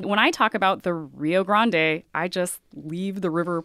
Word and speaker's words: Rio Grande: Rio means river, and you and I are both pronouncing it Rio Grande to try to capture its Spanish origins. You will hear people say the Rio Grande, which is a sort of Englishified version of that.